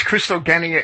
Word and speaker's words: Christogenia: And the G in Greek and Latin is never soft. – Okay. – It's a hard G, It's Christogenia.